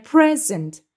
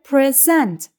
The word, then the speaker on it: present: Örneğin hediye anlamındaki present kelimesinde vurgu ilk hecededir. Yani present şeklinde telaffuz edilir. Ancak sunmak anlamına gelen present fiilinde vurgu ikinci hecededir ve present şeklinde telaffuz edilir.